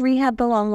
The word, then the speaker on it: Rehoboam: But this week, we talked about Rehoboam. I did go online after yesterday and was like, how to say this name? And they said Rehoboam, like rehab. – Yeah. – And then Oum.